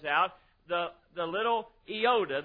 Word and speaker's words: iota: It is pronounced iota, I-O-T-A, iota.